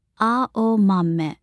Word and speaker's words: Aomame: written with exactly the same characters as the word for green peas, and pronounced with the same four syllables, Aomame.